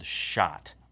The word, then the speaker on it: Schott: And, motorcycling or in the world of motorcycle jackets, there's a company called Schott, which is spelled S-C-H-O-T-T. Schott. – Yeah. – Oh, or is it S-H-O-T-T? – No, it's S-C-H-O-T-T, yeah, but whatever.